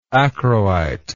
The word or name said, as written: achroite